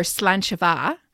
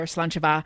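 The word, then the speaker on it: sláinte mhath: You say slancha or slanchava. She writes it out phonetically. – Yeah. – So it looks like slanjavar. – Which so you get the kind of idea of how it sounds.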